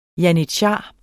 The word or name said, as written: janitshar